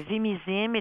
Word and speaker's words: Vimizim: Et au Québec, donc, on ne rembourse pas ce médicament qui s'appelle le Vimizim. C'est comme ça que vous le prononcez? – Tout à fait. Le Vimizim est un médicament qui a été sous effet clinique, en essai clinique, depuis bientôt 5 ans.